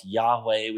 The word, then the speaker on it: Yahweh: Lord's a translation of four consonants, YHWH. We sometimes pronounce Yahweh, which means he is.